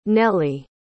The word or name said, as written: neli